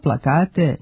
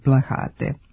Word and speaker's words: plakat: Ni plakat nije naša reč. Uzeli smo je iz nemačkog, a ponekad u govoru može da se čuje kao plahat sa h, što je nepravilno.